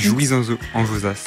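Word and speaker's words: Jouy-en-Josas: Une marche, course, fête solidaire, enfin un peu de tout, est organisée au domaine de la Cour Roland à Joux. En Josas, je sais pas si je le dis bien, c'est dans le 78. – Je crois que c'est Jouy en Josas.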